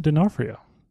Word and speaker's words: D'Onofrio: This movie stars Vincent D'Onofrio. – Okay. – Did I pronounce his name correctly? – Yes, you did.